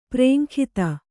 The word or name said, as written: ಪರೇಂಗಿತ